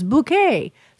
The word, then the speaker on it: Bucket: Okay, well, see, this is an odd way to start the show, but her name is spelled B-U-C-K-E-T, but she says it's Bucket, but she says her name is Bouquet.